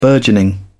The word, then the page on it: burgeoning: British English